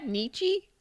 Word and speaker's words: Nietzsche: Nietzsche? – Yep.